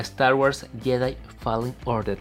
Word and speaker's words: Star Wars Jedi Fallen Order: Pero entonces, like o no like a Star Wars Jedi Fallen Order.